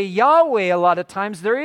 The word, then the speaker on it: Yahweh: But historically it was a W sound. They've changed that with modern Hebrew. So it was, if it is those vowels, it would be Yahweh, would be the correct pronunciation.